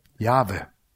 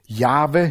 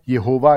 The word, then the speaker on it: Jahwe: Jahwe hinkt, wobei das am nächsten dran ist, von der Aussprache. Jehova ist grottenfalsch.